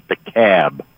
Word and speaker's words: DeKalb: I'm from Illinois, and we pronounce it DeKalb.